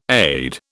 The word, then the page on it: aide: British English